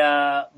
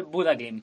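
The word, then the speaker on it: Bodø/Glimt: bodoglim, Bodo Glim, o pronunciado de la forma correcta más parecido al noruego, es algo así como el Buda Glim.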